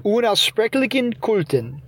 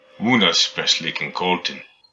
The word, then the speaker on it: Unaussprechlichen Kulten: But first, let's remember had H sound in the middle of Unaussprechlichen Kulten. – Unaussprechlichen Kulten. – And he's got the hard CH, the K sound in.